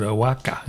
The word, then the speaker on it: Oaxaca: Cale Tyson, and you write it Oaxaca, but you pronounce it Oaxaca.